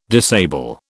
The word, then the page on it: disable: British English